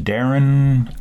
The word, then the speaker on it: Darren: And my name's Darren Auk. – Spell it. – D-A-R-R-E-N, and the last name is A-U-C-K.